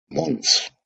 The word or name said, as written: Mons